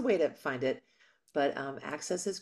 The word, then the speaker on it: AXS: And this is on what's the network? Access? Is that how you Yes. – pronounce it? – AXS, Access TV, AXS TV. And yeah, it's like good old fashioned cable.